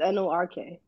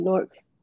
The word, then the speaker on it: Newark: It's NORK. That's how we say it, Newark.